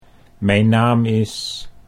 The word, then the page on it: mijn naam is: Dutch